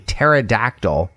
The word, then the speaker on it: pterodactyl: So this joke is, why is it impossible to hear a pterodactyl, that's a dinosaur, going to the bathroom? – Why is that? – Because the P is silent. Pterodactyl, P-T-E-R-O-D-A-C-T-Y-L.